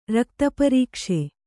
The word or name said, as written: ರಕ್ತಪರೀಕ್ಷೆ